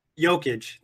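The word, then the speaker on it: Jokić: Jokic? – Jokic. Think Jokic. About yokes. – Damn, yokes. – Think about a yoke.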